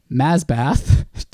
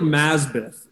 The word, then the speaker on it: Masbath: The victim's son, Masbath, I can't say that without laughing. – Well, then try saying it more like Masbeth. That's how they say it.